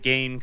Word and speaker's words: Gane: He said he's okay with people pronouncing it Gane because it sounds like Bane from Batman.